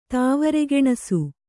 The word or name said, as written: ತಾವರೆಗೆಣಸು